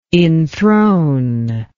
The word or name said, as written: inthrone